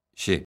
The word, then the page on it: x: lingua galega